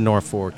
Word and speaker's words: Norfolk: It's the Norfolk. – Oh, I was pronouncing it the Virginian way.